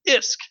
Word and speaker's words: ISK: We were kind of, joking about it before the show, but, as you know, in EVE Online, the currency is spelled ISK, and it's pronounced ISK. In the Valkyrie, it is apparently a different currency called VISK, or V-I-S-K, which I think is actually kind of interesting.